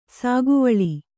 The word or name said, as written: ಸೌಗವಲ್ಲಿ